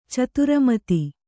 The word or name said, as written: ಚತುರಮತಿ